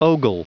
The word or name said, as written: ogle